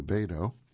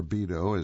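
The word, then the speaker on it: Beato: Beta Path to Beto. In Beto, or Beto, is how a lot of people would pronounce it, because it's B-E-A-T-O is how they spell it, and Marvela.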